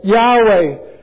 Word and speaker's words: Yahweh: People say now we should pronounce it Yahweh, Jehovah the I Am who I am.